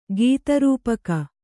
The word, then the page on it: ಗೀತರೂಪಕ: gīta rūpaka